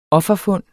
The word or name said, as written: offerfund